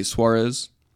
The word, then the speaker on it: Suarez: I usually just say Suarez. – Yeah. – You know, that W sound with a strong Z.